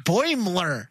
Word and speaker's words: Boimler: with Fletcher and Mariner and Boilermer. – Yeah. – Boimler. – Yeah. You're the one who was saying it right last week.